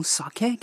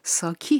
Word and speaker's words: ساکه: امروز از نهونشو خواهیم گفت، یا همون ساکه که در خارج از ژاپن ساکی هم تلفظ میشه.